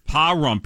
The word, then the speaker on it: Pahrump: But these days, she's living in the outskirts of, I've never heard of this, Pahrump, Nevada. – Pahrump? – Pahrump, P-A-H-R-U-M-P, Nevada. I hope I'm pronouncing it right, like Carter Ray, New Jersey.